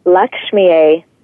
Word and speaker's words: Lakshmiye: So the mantra itself sounds like this: Om Shrim Lakshmiye Namaha.